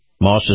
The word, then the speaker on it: Maus: Moss is spelled M-A-U-S, and of course, Acura.